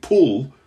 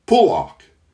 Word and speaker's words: Pulock: And what they have for number six's last name is emphasis on the P-U-H-L, which I'm pronouncing Pulock. Pulock.